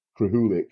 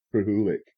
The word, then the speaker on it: Krahulik: I assume it's said Krahulik. I think it's Mike Krahulik. That's how you say it.